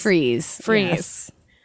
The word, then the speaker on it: Fry's: Freeze. Yes. – Well, spelled like fries, pronounced freeze. Makes no sense.